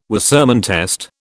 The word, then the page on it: Wassermann test: British English